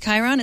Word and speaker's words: Chiron: I'm also not convinced that it's pronounced Chiron. – Okay, I think it might be a shy. I don't know who. It's Chiron. It's not shy. It's not a shh sound.